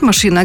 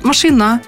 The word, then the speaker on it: машина: Только не говорят «машина», а говорят «машина». Потому что у нас во всех словах, можно сказать, ударение падает на последний слог.